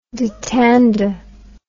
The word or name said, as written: dittander